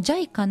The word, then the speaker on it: JICA: 大文字で JICA と書いて JICA と読みます。こちらで検索をしてみてください。また今月号、 10 月号の広報舞鶴にも、 30 ページに、載っております。こちらはこの JICA のボランティア募集説明会についてのご案内が載っておりますので、そちらもぜひチェックをしてみていただきたいと思います。